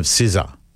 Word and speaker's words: SZA: She is a fan of SZA. – Right. – Now, that's not how it's spelled. – Because it's spelled S-Z-A, yeah.